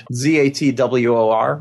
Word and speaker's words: Zatwor: I don't know if there's that much of a break between it, because it's one word. Zatwor, right? – Mm-hmm.